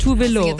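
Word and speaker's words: Tove Lo: Die Leute haben einfach immer Tovlo gesagt, außer in Schweden, und dann habe ich das einfach akzeptiert. Irgendwann hat RuPaul es aber richtig gesagt, auf die schwedische Weise. Dann gab es das Meme, wo Megan Thee Stallion meinen Namen richtig sagt. Und jetzt denke ich mir so, ja, es ist Tovlo.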